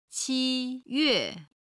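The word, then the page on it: 七月: qi1yue4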